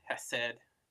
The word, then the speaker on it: Hesed: I don't make the like guttural sounds. So I would also pronounce it Hesed and Shalom.